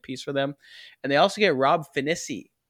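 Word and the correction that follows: Phinisee: I emphasize the wrong syllable there. Finissey from Indiana.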